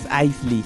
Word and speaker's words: Isley: ¿Quién es Pamela Ashley? – Wey, Poison Ivy. – Pero Isley. No es Ashley, es Isley.